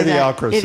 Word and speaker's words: Idiocracy: How do you say Idiocracy. – That? Idiocracy.